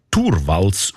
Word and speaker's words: Torvalds: Der Linus Torvalds, eigentlich Torvalds ausgesprochen, dazumal Informatikstudent Helsinki, er hat die wertvollste Software der Welt namens Linux einfach verschenkt.